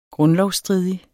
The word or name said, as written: grundlovsstridig